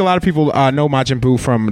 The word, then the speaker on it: Majin Buu: Majin Buu. Yeah, Majin Buu. – Majin. – Majin. – M-A-J-I-N. – Correct. And Buu is spelled B-U-U.